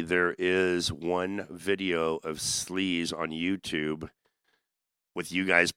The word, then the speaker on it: Sleze: That way, S-L-E-Z-E, Slez.